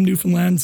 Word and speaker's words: Newfoundland: And I also love that you pronounced Newfoundland correctly. That sounds so good to hear. – How do people normally... – Newfoundland, we get Newfoundland. Newfoundland and Newfoundland, and I can't blame them because it's not how you think it'd be pronounced, but it's really refreshing when I hear it pronounced correctly, so thank you.